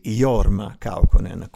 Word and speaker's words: Jorma Kaukonen: anzi Jorma Kaukonen, come si pronuncia correttamente.